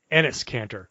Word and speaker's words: Enes Kanter: Enes Kanter.